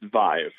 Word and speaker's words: Vive: Is it Vive or Vive? – It's Vive. – Okay. Sorry. Of course, it's how it's spelled.